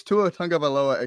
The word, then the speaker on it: Tua Tagovailoa: It's Tua Tagovailoa at QB. That is how you pronounce his name, but so many people do not pronounce it correctly, so he's the easy 101 at QB for the tough name.